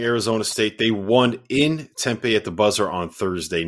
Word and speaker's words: Tempe: Tempe. Okay. That's that's how the, that's how the locals pronounce it, Jeff.